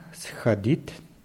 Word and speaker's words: сходит: Anna сходит на фитнес, а потом пойдет домой. – Ja, richtig. Nur bei schadit ist es wichtig, die richtige Betonung da aufzupassen. Anna schodet immer auf O.